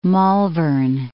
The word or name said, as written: Malvern Hills